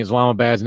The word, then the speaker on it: Islamabad: Instead, it is Islamabad, A-B-A-D. It's all in the pronunciation, you see. Changing Islamabad's name to Islamagood.